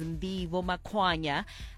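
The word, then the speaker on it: Ndivho Makwanya: My name is Ndivo Makwanya. It is spelled out as N-D-I-V-H-O.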